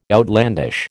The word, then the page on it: outlandish: American English